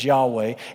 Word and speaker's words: Yahweh: Older tradition tended to pronounce this word as Jehovah. Newer tradition tends to pronounce it as Yahweh.